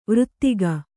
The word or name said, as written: ವ್ರತಿಕ